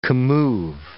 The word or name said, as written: commove